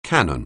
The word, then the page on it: canon: anglais